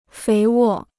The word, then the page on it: 肥沃: féi wò